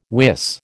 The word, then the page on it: wis: American English